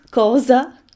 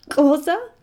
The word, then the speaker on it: Xhosa: There's a funny click, there's a kind of in it that I can't do, but it's Xhosa, Xhosa. I can't do it. The South Africans can do it. It's spelled Xhosa.